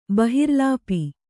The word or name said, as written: ಬಹಿರ್ಲೇಪ